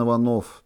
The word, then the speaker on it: Иванов: Иванов, так же, как тысячи его однофамильцев с ударением на О.